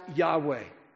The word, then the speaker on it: Yahweh: But the best people have been able to suggest is it should be pronounced Yahweh.